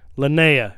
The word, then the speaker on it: Linnea: Like they were like, shit, we can't get in Lanita. Oh my, I can never say her name. – Lanita. Suzanne. – Linnea Quigley.